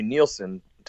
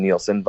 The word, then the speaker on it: Nielsen: Yeah, it's actually a cover that we did with Cheap Trick of a Harry Nielsen song, which, not to be confused with Rick Nielsen or Daxx Nielsen, but same pronunciation, different spelling.